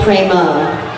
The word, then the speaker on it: prema: You see, in the second line, it says Sadhu Prema. – Видите во второй строчке Sadhu Prema. – Now, usually the word is prema with a short a at the end.